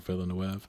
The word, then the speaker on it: Villeneuve: I don't know how you pronounce his last name. Villeneuve or Villeneuve? – I said Villeneuve.